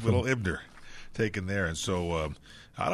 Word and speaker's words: Eibner: Now, the Dodgers also acquired Brett Ebner. I believe that's the way you say his name, E-I-B-N-E-R.